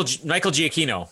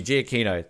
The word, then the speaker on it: Giacchino: Oh, Michael Giacchino. – Thank you, Giacchino.